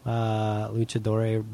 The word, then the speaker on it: luchador: Is that how you say it, luchadore? – Luchador, yeah. – Luchador. Luchadore would be feminine. Luchadoro. I don't know what I'm saying. – No, I think it's just luchador.